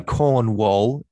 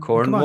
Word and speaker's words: Cornwall: Say Cornwall, Cornwall. – That's true. Yeah, don't say American, man. Come on, you've lived in Britain. – Cornwall. – Come on, say it properly. – Cornwall.